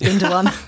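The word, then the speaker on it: Gündoğan: You should pronounce his name right. It's Gundogan.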